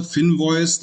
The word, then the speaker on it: Finvoice: Genau, also erstmal der Name Finvoice, das ist einmal das englische Wort Invoice für, für Rechnung ne, und dann halt das F für Finanzen. Ja? Ah. ja, du hast es richtig gesagt.